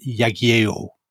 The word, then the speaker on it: Jagiełło: So Poland and Lithuania have the same king, who is Vladislav Jagiełło.